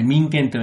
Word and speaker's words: 民権: それから民衆の民という字にですね、権力の権と書いて、これ民権と読みます。